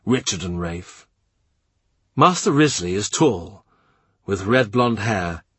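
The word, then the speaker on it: Wriothesley: Towards the end of the alphabet comes Thomas Riothesley, pronounced Risley.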